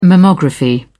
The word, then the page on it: mammography: British English